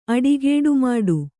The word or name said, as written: ಅಡಿಗೇಡುಮಾಡು